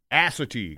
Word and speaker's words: Assateague: Assateague Island outside of Ocean City, Maryland, right? – Emphasis on the ass.